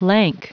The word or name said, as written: lank